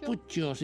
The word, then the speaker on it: Puccio: Los Puccios, si quieres pronunciarla en italiano, Puccio.